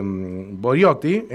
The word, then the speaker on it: Boriotti: Vamos a hablar con María Fernanda este, Boriotti, lo dije bien, María Fernanda, buen día, ¿cómo te va? – Hola, ¿cómo les va? Perfecto, lo dijiste.